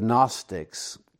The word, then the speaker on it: Gnostic: The word Gnostic is spelled G-N-O-S-T-I-C. It's a silent G. We say Gnostic, but it's spelled with a G, G-N-O-S-T-I-C.